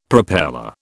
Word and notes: propeller: British English

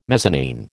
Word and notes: mezzanine: American English

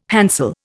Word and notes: hansel: Indian

